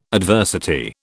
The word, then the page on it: adversity: British English